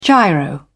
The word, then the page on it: giro: British English